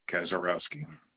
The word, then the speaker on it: Kaczorowski: Greg Kazarowski.